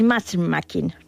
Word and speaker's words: matchmaking: Se utilizan, que lo has pronunciado muy bien, el matchmaking, ¿no?